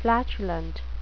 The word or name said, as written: flatulent